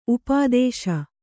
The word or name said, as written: ಉಪದೇಶಿ